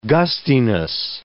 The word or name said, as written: gustiness